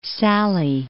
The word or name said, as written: Sallee